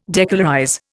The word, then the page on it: decolorize: Indian